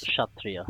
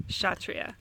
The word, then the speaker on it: Kshatriya: Sh- Kshatriya. Kshatriya.